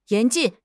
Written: 严禁

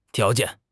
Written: tiao2 jian4

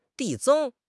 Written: 递增

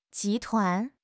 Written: 集团

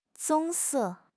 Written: 棕色

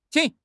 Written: qing3

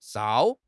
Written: 哨

